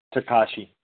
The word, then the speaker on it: Takashi: Tashiki uh, Meliora. I- I- am I saying that right? Takashi. Takashi. Takashi. Uh, Takashi. Um, Miura, round nine.